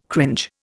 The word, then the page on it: cringe: Indian